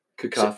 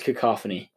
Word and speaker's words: cacophony: There's a few where I'm like a little bit whatever, but can you hear what this word sounds like pronounced? – Probably. – Cacophony. So it is cacophony.